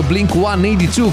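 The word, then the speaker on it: Blink-182: Fra gli altri è stato l'anno anche dei Blink 182 o Blink 182 come andrebbero pronunciati,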